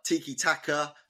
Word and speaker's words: tiki-taka: They're tiki-taki, tiki-taka. I keep saying that wrong.